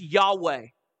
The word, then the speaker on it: Yahweh: Sometimes pronounced Yahweh.